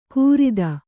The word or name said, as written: ಕೂರಿದ